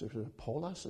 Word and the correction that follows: poultice: is that what you would say, call it? Of figs. What is it? Poultice.